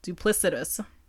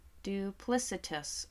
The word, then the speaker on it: duplicitous: How do you say it? – Duplicitous. – Duplicitous. Okay, it honestly, like, I see it now.